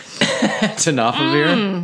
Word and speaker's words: tenofovir: Tenofovir? Mm. What was it? Is that tenofovir? – Yeah, tenofovir. – Yeah. – T-E-N-O-F-O-V-I-R. Yes.